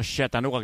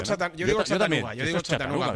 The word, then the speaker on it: Chattanooga: No Chattanooga, que decía hoy Paco Jó. – Yo digo Chattanooga. Pero sí. Si Paco Jó tiene más arte para pronunciarlo, pues fantástico. Pues no, vamos a dejarlo en Chattanooga.